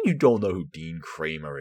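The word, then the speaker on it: Kremer: Okay, it's spelled with an E, not with an A, but it's pronounced Kramer.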